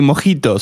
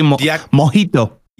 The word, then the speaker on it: mojito: Well, it's actually mojito, so... – It's from Cuba and I believe... They pronounce the J kind of silently, kind of like a H. – They say mojito.